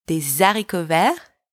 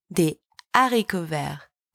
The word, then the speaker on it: des haricots verts: So you don't say des haricots verts. You say des haricots verts. Do you hear the difference? Des haricots verts. It's wrong. Des haricots verts. That's right.